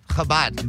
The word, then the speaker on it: Chabad: The store is on Fairfax, right next to the Chabad. Is that how you say it? – Chabad. – The Chabad. – Yeah, Chabad, right next door to it.